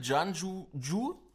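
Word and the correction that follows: Guanyu Zhou: Guanju Ju oder.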